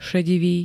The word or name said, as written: ŠEDIVÝ